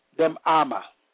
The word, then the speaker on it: demama: The word still small voice in the Hebrew is pronounced demama, meaning quiet, calm, silence.